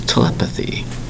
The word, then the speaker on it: telepathy: Today's word is telepathy. – Telepathy. – Four distinct syllables for telepathy, spelled T E L E P A T H Y.